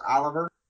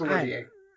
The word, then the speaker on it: Olivier: And isn't it Laurence Olivier, not Laurence Oliver? It's pronounced Olivier.